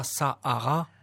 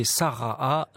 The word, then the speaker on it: Sarah: Sarah, c'est pas Sahara, c'est Sarah. Nous, on le prononce comme ça.